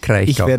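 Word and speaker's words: Kraichgau: Und du sagst immer Breisgau. – Das erste Mal habe ich das falsch gesagt. – Kreichgau. – Kommt von Griechen.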